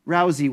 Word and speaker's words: Rousey: Rousey. – Rousey with a Z. Thank you.